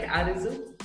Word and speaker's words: AREZOU: Am I pronouncing it right, AREZOU? – Yeah. Okay. It's fine.